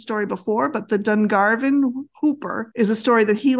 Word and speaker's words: Whooper: I looked and it says it's supposed to be pronounced Hooper, but it looks like it should be Whooper.